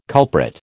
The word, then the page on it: culprit: American English